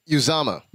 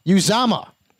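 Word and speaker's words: Uzomah: This is how it's pronounced. – Uzama. – Uzama. Uzama. – Yep.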